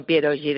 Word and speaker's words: pierogi: ¿Qué lleva el pierogi de patata de Marie Curie. No sé si lo he dicho bien. – Sí, sí, son pierogi de patata.